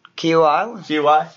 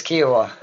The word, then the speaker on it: Kiawah: Kiawah? – Yeah. No, okay, I always want to say Kauai because obviously. – I think it's Kiawah.